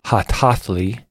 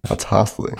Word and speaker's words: Hatathlii: This is an artist called Hatathli. – Hatathli. – Hatathli. – Okay. – It's spelled Hatathlii.